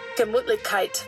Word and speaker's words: Gemütlichkeit: Well, let's see what the pronunciation lady says. – Gemütlichkeit. – Oh, that was pretty good.